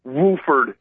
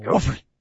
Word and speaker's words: Wofford: You don't have time to figure out what's going on between, Wofford and East Tennessee State. – Wofford.